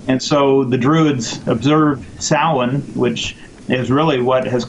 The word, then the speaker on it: Samhain: It's actually written Samhain, but it's pronounced Samhain, and that's an Irish Gaelic term, and that in Gaelic means summer's end.